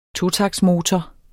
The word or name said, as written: totaktsmotor